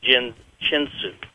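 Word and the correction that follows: Jin Shin Jyutsu: I'm sorry, Jinsen Jitsu.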